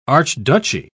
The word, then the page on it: archduchy: British English